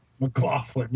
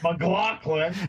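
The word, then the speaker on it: McLachlan: There's Sarah McLaughlin. – McLaughlin, yeah, that's how you pronounce it. McLaughlin.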